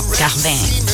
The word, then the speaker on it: Cardin: Acuérdense que la I no se dice I, Cardin, no. – Cardin.